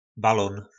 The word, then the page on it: balon: po nasimu